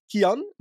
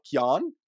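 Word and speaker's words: Cian: And first of all, it's Kian, not Kian. Just wanted to get that out there. Kian Uytterbroeks is his name. That's how you pronounce it.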